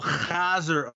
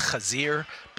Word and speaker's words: chazir: It's actually pronounced chazir, but Todd speaks a fakakta kind of Hebrew that is a Yiddish influence.